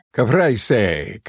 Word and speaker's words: Caprese: Caprese. – Thank you. I knew you could say it properly.